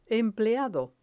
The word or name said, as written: empleado